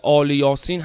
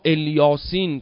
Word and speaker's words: الیاسین: در آیه قرآن سلام علی الیاسین که قرائات مختلفی هم داره، آل یاسین هم قرائت شده ولی اکثر قراء الیاسین تلفظ کردن.